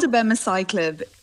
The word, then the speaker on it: abemaciclib: So it's called abemaciclib.